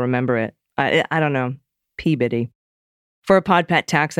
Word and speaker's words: Peabody: The one everyone gets wrong is Peabody. It's Peabody.